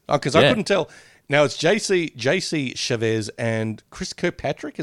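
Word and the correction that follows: Chasez: It says Chavez. It's S in his name. It's Chazez, isn't it? – Chazez or Chavez? – It's C-H-A-S-E-Z. – Oh, okay then. Oh, Chazez. Yeah. Okay then.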